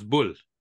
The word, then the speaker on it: Boulle: I'll give you my Americanized pronunciation. You can do the French one. So I read the original Planet of the Apes novel, which is written by Pierre Boulle. – Oh, it's Boulle.